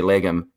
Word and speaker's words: Legum: Most people can, but I've heard I've heard your last name, which I say Legum, said in about 160 different ways. – It's not something that's typically pronounced correctly, but you did do it correctly.